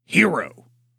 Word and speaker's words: Hiro: His name is spelled H-I-R-O. And is pronounced like the word hero.